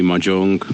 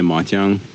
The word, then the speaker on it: mahjong: Číňané mají různé hry, ať jsou to různé karetní hry, potom samozřejmě čínské šachy a nejznámější pro všechny asi bude u nás vyslovovaný mahjong nebo v Číně máťang.